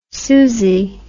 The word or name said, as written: suzy